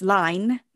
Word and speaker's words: Lyne: And I did actually contemplate changing the spelling of my name when I first published, because my surname is Lyne, it's spelled L-Y-N-E, and all my life, about 95% of people pronounce it wrong until I correct them. And even then, some will persist in deliberately winding saying it wrong. – Yes. – I did consider just spelling it L-I-N-E, so there's no mistaking the pronunciation on that.